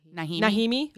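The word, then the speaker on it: Najimy: It's N-A-J-I-M-Y. – Nahimi. – Okay. – Nahimi.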